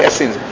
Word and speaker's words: Essenes: Is that how you say it in English? Isim is what we say in English? – Essenes. – Essens. – Essens. – In English they say Essens.